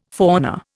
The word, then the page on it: fauna: Indian